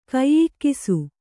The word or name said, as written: ಕೈಯಿಕ್ಕಿಸು